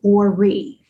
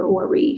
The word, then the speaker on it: Ori: And she said, they did something for my Ori. And you spell that O-R-I.